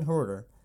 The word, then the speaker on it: Huerter: Kevin, playoff Kevin, I didn't imagine him, Kevin Huerter, that's really the pronunciation, I always muck it up, but he dropped 25 points, beat the 76ers.